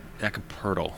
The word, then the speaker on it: Jakob Poeltl: Jakob Poeltl.